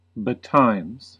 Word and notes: betimes: American English

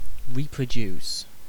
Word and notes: reproduce: British English

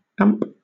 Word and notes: amp: British English

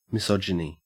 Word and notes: misogyny: Australian English

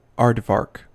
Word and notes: aardvark: American English

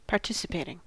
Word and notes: participating: American English

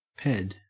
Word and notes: ped: Australian English

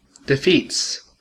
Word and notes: defeats: American English

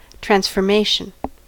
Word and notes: transformation: American English

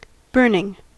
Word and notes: burning: American English

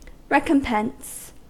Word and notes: recompense: American English

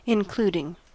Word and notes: including: American English

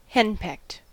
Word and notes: henpecked: American English

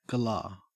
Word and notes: galah: Australian English

